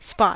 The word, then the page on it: spot: American English